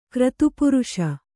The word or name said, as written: ಕ್ರತುಪುರುಷ